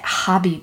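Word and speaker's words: Gabie: I've never met anyone who spells Gabby the way I do, but you pronounce it Hobby because that's Dutch.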